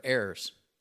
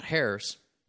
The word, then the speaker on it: heirs: It's just one word, heirs. Not hairs. You don't want to find those in your meal in a restaurant, I tell you. Yikes. But heirs. The H is silent.